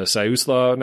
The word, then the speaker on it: Siuslaw: Siuslaw National Forest.